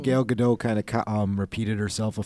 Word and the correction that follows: Gal Gadot: He was pronouncing it Gal Gadot, and I'm like, am I pronouncing it wrong? It is Gal Gadot. Sorry, I feel bad. I'm like, I've been saying Gal Gadot this whole time.